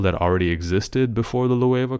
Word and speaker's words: Loewe: Is it Le or Loewe? – Loewe, okay. This is the latest collab with Loewe. I didn't even know it was pronounced like that.